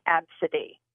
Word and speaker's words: Abcde: It made me think of many years ago, there was a baby who their first name was spelled A, B, C, D, E, and the parents pronounced the name Absidy.